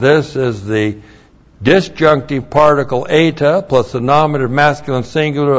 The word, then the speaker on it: eta: Eta. Long E, one letter.